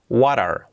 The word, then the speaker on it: water: Water, water, la T como resuave, letra clave que siempre manejamos aquí en You Talk.